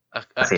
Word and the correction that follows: Arcturus: Arcturus Men, esa marca de ropa es peligrosa.